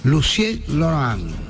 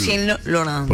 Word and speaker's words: Lucien Laurent: El señor Lucien Laurent. – ¡Mierda! Good pronunciation. – Digo a los oyentes porque fue la que me dijo cómo tenía que decirlo. – Lucien Laurent.